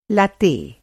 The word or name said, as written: लैट्टे